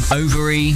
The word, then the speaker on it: Othery: It's the Overy Village Fair. – But it's spelt like Othery. – It's spelled like Othery, yes.